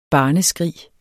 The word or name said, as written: barneskrig